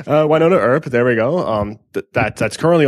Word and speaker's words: Wynonna Earp: You know, they went over a Buffy the Vampire Slayer episode that they created themselves, and she's got her own series, Wynonna Earp, that's just... did I butcher the name? – No, that's pronounced? Perfect.